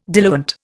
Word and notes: diluent: Indian

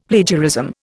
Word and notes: Plagiarism: Indian

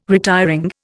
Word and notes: retiring: Indian

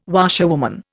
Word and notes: washerwoman: Indian

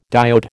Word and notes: diode: American English

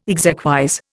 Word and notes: exequies: Indian

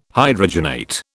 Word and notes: Hydrogenate: British English

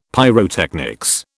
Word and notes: pyrotechnics: British English

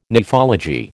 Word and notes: nephology: American English

